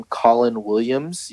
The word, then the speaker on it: Colin Williams: So, you can just search me on YouTube by name Colin Williams. You can, that's spelled C-O-L-I-N and then W-I-L-L-I-A-M-S.